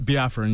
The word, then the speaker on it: Biafran: And then it's Biafran. Is that how you say it? – Mm-hmm. Biafran, yeah.